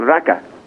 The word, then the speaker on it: raki: Is the drink R A K I? How do you say That's that? That's how it's spelled. It's actually pronounced with a bit of an accent They're called Raka.